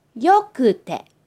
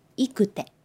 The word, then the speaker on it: よくて: So, when you change いいinto くて, this is the only exception that you have to change the pronunciation. So, いい becomes よくて, よくて. We cannot say いくて.